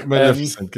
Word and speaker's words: Maleficent: Maleficent. Spricht man das so aus? Ich spreche es immer falsch aus. Genau. Mal, mein, Maleficent, ähm, genau.